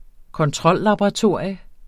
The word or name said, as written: kontrollaboratorium